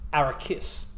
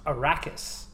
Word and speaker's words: Arrakis: And one of the lines mentions the planet that the most of the book is set on, and it's pronounced Arrakis. However, in the film, it becomes Arrakis. – Well, I can understand why, though, because there's two R's, so there should be more emphasis on the R's than the K, because there's one K in there. – You could try Arrakis.